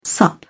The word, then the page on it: sup: British English